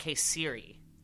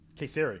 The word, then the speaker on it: Kayseri: You pronounce that Kayseri. – Kayseri. – Kayseri.